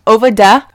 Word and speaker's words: Ovadeh: So if you were to spell it, it might look like W-A-H-G-W-A-A-N, Wagwan. Ovada, O-V-A-D-E-H, over there.